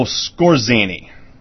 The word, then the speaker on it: Skorzeny: In the Middle East, you had several different elements invite Nazis in after the war, whether it be Otto Scarsese, and I think I pronounced that wrong. It's Otto Skorzeny, Skorzeny,